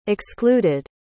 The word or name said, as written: excluded